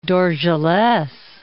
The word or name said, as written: Dorgeles